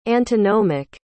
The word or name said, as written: antinomic